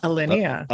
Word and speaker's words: Alinea: Alinea. Did I say the name right? – Yeah, Alinea.